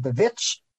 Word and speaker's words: The Witch: he did The Witch. Yes, that's how it's pronounced.